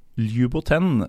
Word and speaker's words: Ljuboten: Og uh, det er da FK Luboten, hvis jeg skulle uttalt det. Hvordan, eh, hvordan, ville du sagt det, Fredrik? – Jeg syns du sa det perfekt, jeg.